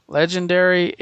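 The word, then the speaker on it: Legenderry: Legend, like legend, and then E-R-R-Y, Legendary, a steampunk adventure number one by Bill Willingham.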